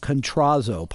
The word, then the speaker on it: Kontrozo: Catanzo. – Oh, you're going to have to spell that for me. I know. K-O-N. – Raymond. – K-O-N-T-R-O-Z-O. Contrazo. Pardon me. Raymond L. Contrazo.